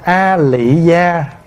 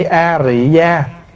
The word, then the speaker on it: A Rị Gia: Thì vị Phật tử mới hỏi á, mình đọc là A Lị Gia đúng hay mình đọc A Rị Gia đúng? Thưa đại chúng, dù là A Lị Gia hay A Rị Gia đều là chữ phiên âm từ tiếng Phạn là Ariya. Nhưng mà so ra giữa chữ A Lị và A Rị á, thì chữ A Rị nó gần hơn.